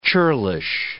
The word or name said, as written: churlish